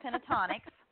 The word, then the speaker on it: Pentatonix: It's actually pronounced pentatonix. – Okay. – Pentatonix, but I think it's kind of like the first T is silent, so it's pentatonix, or at least that's how I've heard it pronounced.